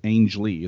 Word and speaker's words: Angley: Ernest Angley Ang, Angley. It's a soft G.